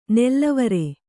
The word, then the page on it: ನೆಲ್ಲವರೆ: nellavare